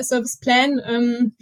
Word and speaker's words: Serviceplan: Sagt ihr Serviceplan oder Serviceplan? – Beides, aber Serviceplan ist weiter verbreitet. Das andere, Serviceplan, wird eher von den englischsprachigen Kollegen und Kolleginnen genutzt.